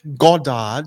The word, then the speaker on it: Goddard: How do you say your name in Aussie? – Goddard. – Goddard is Australian, but I like the way you do it, man.